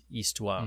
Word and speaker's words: histoire: It's histoire. Mm. It's spelled the same, historie or whatever. And that's all. Good. It's great, isn't it? It's a silent H, so I guess it works.